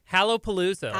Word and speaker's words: Hallowpalooza: Hallowpalooza, like Halloween.